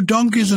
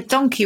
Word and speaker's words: donkey: And originally, a donkey would have been pronounced like monkey. So it was a donkey,